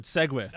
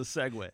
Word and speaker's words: segue: See, what that's called in the biz, is a segue. – It's very good. – Thank you very much. – How do you spell it? – S-E-G-U-E. – Very deceptive word.